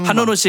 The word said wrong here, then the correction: 한로로: 씨에게 그 실례예요. 한노노 씨. 아, 근데 원래 발음이 다 어렵습니다. – 한로로 씨. – 예, 아까 루루처럼. 네, 네.